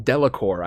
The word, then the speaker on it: Delacour: Finally, the Delacroix evolve. Let's try arrive. I'll Also, what did I just call them? I think it's Delacour. I don't know if I said that right.